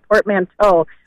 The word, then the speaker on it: portmanteau: I was listening to your conversation of portmanteau, which you did pronounce correctly. Love that.